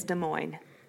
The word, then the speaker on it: Des Moines: Um, if you pronounce, pronounce it Des Moines or Des Moines, you can just go ahead and swipe on out, right on out of this podcast because that is people wrong. People do that. – Yeah. – People just, people pronounce Des Moines Des Moines. – Yeah, Des Moines, Des Moines. Oh gosh. It is Des Moines.